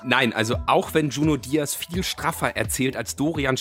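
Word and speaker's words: Junot Díaz: Oder spricht man den dann Junot aus? – Junot Diaz.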